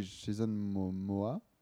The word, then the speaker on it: Jason Momoa: mais Jason Momoa.